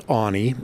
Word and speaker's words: Aune: Yeah, my name is Keith Awney. That's spelled A-U-N-E, and I am the director of bison programs for the Wildlife Conservation Society.